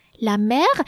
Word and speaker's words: mer: Now, can you think about any other word that would be sounded, that would be pronounced mère and père, and that would not mean mother and father? Is anything coming to mind? What about la mère? So again, another feminine noun. La mer, meaning the sea. La mer Méditerranée, the Mediterranean Sea. And it is spelled M-E-R, la mer.